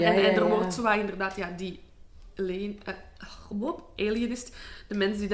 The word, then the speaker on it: Alienist: Ik heb ook heel hard aan de, nu ga ik het weer fout uitspreken, die Lionist? Alienist? – Alienist, ja, ik heb hem nog niet gezien.